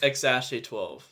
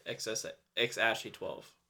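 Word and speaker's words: X Æ A-12: XASHE-12. That's how you say it. XASHE-12.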